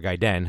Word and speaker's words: Gaiden: and then there was a game called Gradius Gaiden or Gaiden, however you want to pronounce it, which was pretty much it was like a Japanese release.